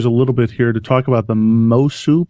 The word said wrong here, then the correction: Mosuo: people. Is that how you pronounce? – Well, I think it's Mosuo. – Mosuo.